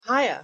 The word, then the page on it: hiya: English